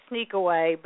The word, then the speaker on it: Sniqueaway: It sounds like Sneakaway, but that's actually how it's spelled.